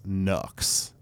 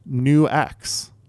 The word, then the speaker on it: Nux: Well, I thought it was Nux. N-U-X. Okay. Have you ever seen that company? Yeah, make pedals and shit. – Uh-huh. – It turns out it's New X.